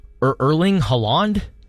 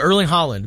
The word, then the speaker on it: Haaland: You wouldn't put the long A where there's more A's. – Yeah. – it's obviously the second A that is longer. – But Erling Haaland, man, got a fan of me.